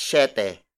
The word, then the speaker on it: siyete: 7 is spelled as S-I-Y-T-E. E-T-E. It means seven. If you notice, Tagalog words with the S-I-Y combination are pronounced as sh. At 7 o'clock, we say alas siete. Alas siete. Alas siete. Alas siete is spelled as A-L-A-S hyphen S-I-Y-E-T-E.